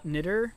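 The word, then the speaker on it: Knitter: A lot of the stuff that I'll be sharing is by a professor and theologian academic named Paul Nitter, and it's spelled like knitting a sweater.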